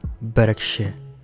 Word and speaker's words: Bereshit: Originally, it was called Bereshit. That is how I pronounce it. It's a Hebrew word and it means in the beginning, which corresponds to the first two words of the book.